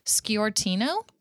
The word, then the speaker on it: Sciortino: Her name is Carly, totally going to butcher this, Sciortino. – And that sounds right to me.